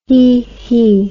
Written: hehe

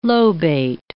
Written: lobate

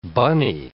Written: bunny

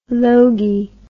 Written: logie

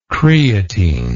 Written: creatine

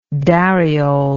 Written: dariole

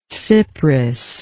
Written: cypris

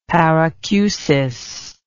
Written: paracusis

